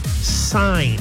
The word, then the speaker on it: sign: Text the national keyword, sign, S-I-G-N. It's a silent G.